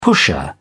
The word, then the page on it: pusher: British English